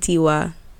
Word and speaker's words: Yitty: How you spell it? – Yitty. – Oh, okay. – Like kitty.